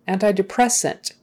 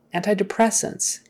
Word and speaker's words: antidepressant: Antidepressant. Again, another long word where the stress is on the second to the last syllable. Antidepressants.